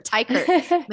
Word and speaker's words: Teichert: Okay. It's Teichert. – Okay. – Tykert, Minerva Tykert.